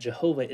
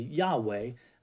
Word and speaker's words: Yahweh: But as people have gotten to know the Hebrew language better and been able to translate it better, we have realized that Jehovah isn't the correct pronunciation of that name, of God's name, and it's actually Yahweh, which is in Hebrew, translated into the English would be YHWH.